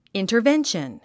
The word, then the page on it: intervention: ìntərvénʃən; インタァベンション